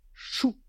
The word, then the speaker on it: coup: Or, dans le Berry, où on parle berrichon, le mot « coup » se prononçait « chou ».